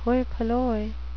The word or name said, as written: hoi polloi